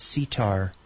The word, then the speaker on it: CTAHR: but then I will put the word sitar. And that's spelled C-T-A-H-R, which stands for the College of Tropical Agriculture and Human Resources.